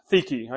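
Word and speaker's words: Dike: which is pronounced theke. I know it doesn't look like that.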